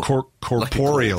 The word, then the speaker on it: corporeal: Is that how you pronounce that word? Corporeal? Corporeal? – Corporeal. Like corporeal. – Corporeal. I knew I'd got that wrong, and I knew people were going to tweet me about it, so I thought I'd just flag it at this point.